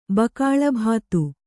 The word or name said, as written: ಭಾಗಲಬ್ಧ